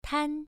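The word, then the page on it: tan: tone 1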